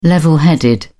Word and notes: level headed: British English

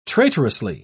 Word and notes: traitorously: British English

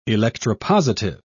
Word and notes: electropositive: British English

